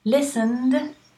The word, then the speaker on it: listened: Listened with an N, the ED is a D.